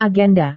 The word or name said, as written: agenda